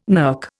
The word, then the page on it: NERC: Indian